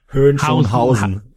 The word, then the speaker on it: Hohenschönhausen: Hohenschönhausen.